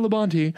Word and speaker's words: Labonte: That's Phil Labonte, pronounced Phil Labonte. It's even pronounced perfectly here.